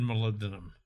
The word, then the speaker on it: molybdenum: So molybdenum, is it, am I saying that right? – Molybdenum, yeah.